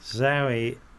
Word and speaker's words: Zowie: Zowie is pronounced like the girl's name Zoe.